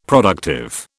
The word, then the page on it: productive: British English